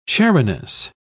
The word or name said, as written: chariness